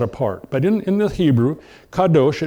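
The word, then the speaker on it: kadosh (קדוש): One is the more prolific general word, and this is, I'm putting up here the English pronunciation of the word kadosh.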